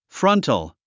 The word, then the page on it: frontal: frʌ́ntl; フラントォ